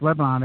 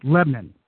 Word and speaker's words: Lebanon: So I live in Lebanon and PA, but it's not pronounced Lebanon. It's Lebanon. That's how the locals, you have to say Lebanon.